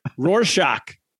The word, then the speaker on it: Rorschach: How do you say that word? – Rorschach.